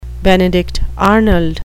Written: benedict arnold

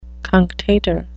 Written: cunctator

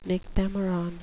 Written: nychthemeron

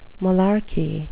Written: malarkey

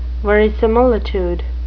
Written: verisimilitude

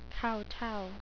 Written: kowtow

